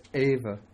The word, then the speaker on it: eva: And then this word eva, it's pronounced eva. And sometimes, especially following a vowel, the E will be dropped and you just get va.